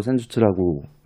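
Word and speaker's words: Senjutsu: 전술할 때 전술을 일본어로 센주츠라고 읽는다고 해요.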